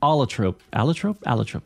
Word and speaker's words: Allotrope: Allotrope, that's how you say it.